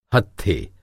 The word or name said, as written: हत्थे